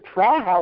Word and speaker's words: Praha: And he says to me, you know, they pronounce it Praha over there.